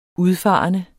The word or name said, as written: udfarende